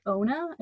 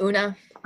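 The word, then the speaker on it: Oona: And then I realized like halfway through the book that her name could be pronounced Ona and not Una, because it's O-O-N-A. But I read the entire... – That's Una.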